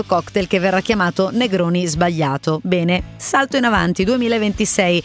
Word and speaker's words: sbagliato: Lei dice Negroni sbagliato, tra l'altro con questa pronuncia, sbagliato.